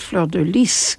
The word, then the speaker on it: fleur de lis: You know, lily, and you're supposed to pronounce it fleur de lis. Lis, you pronounce the S, because if you don't pronounce the S, fleur de lis might be not quite right, because lit means bed. So, you know, be careful how you said. You should pronounce it fleur de lis, L-Y-S, right, Olaf?